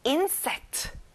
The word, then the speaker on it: insect: 곤충, insect. 인을 세게 말해보세요. Insect.